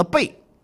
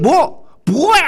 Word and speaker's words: 薄: 然后呢他就把色薄如也当中的一个薄字呢念成了背。这张居正啊在旁边就怒吼说，念错了，念薄薄呀。